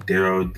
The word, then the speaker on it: Daryl: And like I said, always, you can reach me at my Facebook, at my Instagram with the same name, Daryl the Author, Daryl D-A-R-Y-L, the T-H-E-E, and then author.